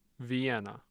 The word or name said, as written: Vienna